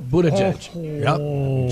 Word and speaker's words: Buttigieg: Did I say his name right? Or is it Buttigieg? – No, Buttigieg. – Judge a butt? Yep. Backwards? – Judge a butt, yeah.